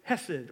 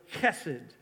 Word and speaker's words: hesed: In the Hebrew, the word is hesed or chesed. It's spelled H-E-S-E-D.